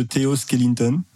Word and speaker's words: Theo Skellington: Sous le nom de Theo Skellington.